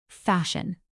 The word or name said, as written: fashion